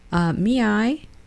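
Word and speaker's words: ME:I: What is me-I? Me-I, it's spelled M-E colon I.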